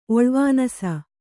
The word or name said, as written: ಒಳ್ವಾನಸ